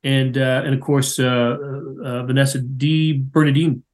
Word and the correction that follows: DiBernardo: DiBernardo. Yeah. – DiBernardo. Thank you.